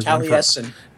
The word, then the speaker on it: Taliesin: And there was like Taelsian or something like that was Merlin. And there was Taliesin.